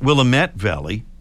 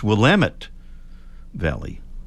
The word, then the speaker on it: Willamette: It's not Willamette Valley, it's Willamette Valley.